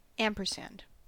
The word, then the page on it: ampersand: American English